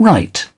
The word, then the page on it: right: British English